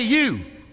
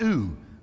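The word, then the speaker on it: ewe: Generally, an arrogant, cunning, and domineering old you, that's how you say it, "Hey, you." For the longest time, I went, "Ooh." You know, an ooh in the field, and I was wrong. It's you.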